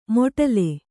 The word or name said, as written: ಮೊಟಲೆ